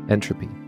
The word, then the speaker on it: Ntropy: My guest today is Naré Vardanyan, co-founder and CEO of Ntropy, spelled with the capital letter N, a play on the word entropy, used in thermodynamics modeling.